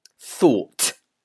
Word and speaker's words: thought: So, yeah, in this word, you've got that letter G, but we don't pronounce that. We do pronounce the T at the end. So it must be strong. Thought.